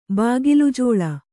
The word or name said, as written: ಬಾಗಿಲುಜೋಳ